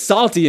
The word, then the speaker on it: Psalty: Yeah, don't get salty in here on me. No, that's not what I'm talking about. No, Salty was his name, spelled like the Psalms, right? P-S-A-L-T-Y. Salty, the singing songbook, right?